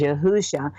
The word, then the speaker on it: Yahusha: And you know, I call him, the audience knows, I called him Jesus for a while, but then he kind of introduced me to his Paleo-Hebrew pronunciation, which is Yahusha.